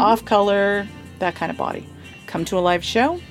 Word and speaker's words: Bawdy: Yes, I have a podcast, the Body Storytelling Podcast. Body is spelled B-A-W-D-Y, not B-O-D-Y. People get confused about that one. Mm-hmm. Body, like Mae West, like...